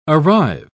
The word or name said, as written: arrive